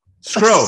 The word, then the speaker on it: Scro: And Scott, I gotta correct you. My last name is Scro. – Scro. – Sorry, Scro. Yes, I was wondering. I was like, did I been saying it wrong? – I don't know.